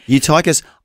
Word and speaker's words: Eutychus: Eutychus.